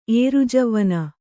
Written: ಏರುಜವ್ವನೆ

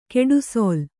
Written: ಗುಡಿಸಿಲು

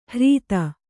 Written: ಹ್ರೀತ

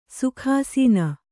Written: ಸುಖಾಸನ

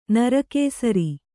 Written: ನರಕೇಸರಿ

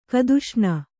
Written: ಕದುಷ್ಣ

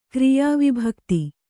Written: ಕ್ರಿಯಾವಿಭಕ್ತಿ

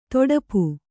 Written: ತುಡುಪು

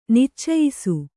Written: ನಿಚ್ಚಯಿಸು

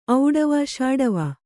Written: ಔಡವ ಷಾಡವ

